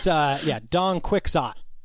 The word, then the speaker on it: Don Quixote: How's it pronounced? – I just hear it as Don Quixote from you. – No, it's Don Quixote.